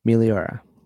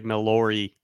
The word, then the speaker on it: Meliora: Meliora. – Okay, because I can't pronounce it right. Every time I do, it's like Melori.